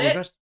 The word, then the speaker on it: Segrest: You got Segrest.